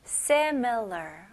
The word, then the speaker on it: similar: similar. 오케이? 강세는 앞부분이에요. similar.